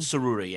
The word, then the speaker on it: Tsuruta: Tsuruta. Yeah. TS is like an S. Like the PH is an F.